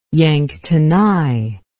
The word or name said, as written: yanktonai